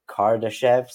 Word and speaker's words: Kardashev: So the, correct me if I'm saying this wrong, the Kardashev scale.